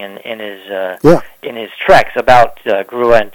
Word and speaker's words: Grua: I know that, you know, Harvey had looked into it, and then Kenton Grau had Grua, G-R-U-A, yeah. – Grua, okay. I've never, you know, been aware of how that should be pronounced.